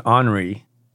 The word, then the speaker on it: Henri: We had an Henri, pronounced the French way, but no Joyce.